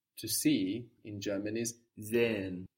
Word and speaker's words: sehen: Sehen is spelled S-E-H-E-N. And again, we have that H stretching out the vowel. – Sehen. – Unless the word is pronounced really slowly or emphatically, you're not going to hear that H. It's just an extension of the vowel. But if somebody pronounces the word slowly for you, you might hear sehen. You might feel like you're hearing that H. But in fluent speech, you won't hear it.